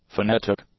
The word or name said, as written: phonetic